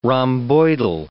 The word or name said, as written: rhomboidal